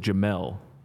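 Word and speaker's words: Jamel Dean: Jamal Dean, third round pick in 2019. He's been played well in the league. – I believe it is Jamel.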